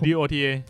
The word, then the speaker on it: Dota: ตกลงอ่านว่า .a หรือ Dota หรือ DOTA Dota